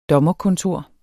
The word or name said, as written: dommerkontor